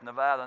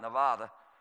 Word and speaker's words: Nevada: Daniel mentioned that we lived in Nevada for a while, and if you live there, it's Nevada, not Nevada.